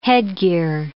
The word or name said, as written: headgear